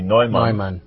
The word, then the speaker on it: Neumann: So tell us a little bit about, St. John Neumann, or the correct pronunciation would be Neumann. The German pronunciation.